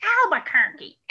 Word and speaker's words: Albuquerque: We would say, well, how is Albuquerque? Just to make her mad enough to say, it's pronounced Albuquerque, Albuquerque. But we always called it Albuquerque just to make her nuts.